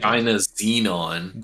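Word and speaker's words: Dynazenon: So, when you said you were watching SSSS Dizenon, I was very excited. – Dinazenon. – Dinazenon, my bad.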